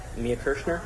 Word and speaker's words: Mia Kirshner: I'm talking to Mia Kirshner, is that how you pronounce it? – Mm-hmm.